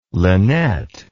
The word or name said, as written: lynette